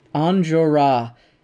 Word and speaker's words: Enjolras: Enjolras. Fucking French and their silent S's.